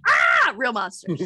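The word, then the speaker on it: Aaahh!!! Real Monsters: Big fan of Beetlejuice, Tales from the Crypt Keeper, All Real Monsters. And even you said that wrong. Shouldn't it be like, ah, Real Monsters? – It should.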